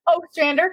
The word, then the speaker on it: Ostrander: Ostrander, kind of like O'Malley, but without okay. The little comma.